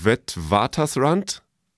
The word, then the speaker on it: Witwatersrand: Die Aussprache in Afrikaans ist wohl Witwatersrand, aber ich kann kein Afrikaans, deswegen noch nie gehört, aber die haben sich das da überlegt.